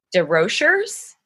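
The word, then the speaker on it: DesRochers: So this is survivor Amelia DeRochers. It's DeRochers. So I think the first S is silent because like there's a lot of French up in that area. So DeRocher, yeah. DeRocher.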